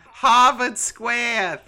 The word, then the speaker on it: Harvard Square: I recently saw the Rocky Horror Picture Show at the Havad Squat Theater in Cambridge, Massachusetts. – This is so long. – How do I say that? – Harvard Square Theater.